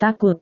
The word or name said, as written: takut